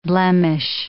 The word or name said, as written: blemish